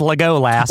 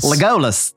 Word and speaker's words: Legolas: pronounced Legolas. – Legolas.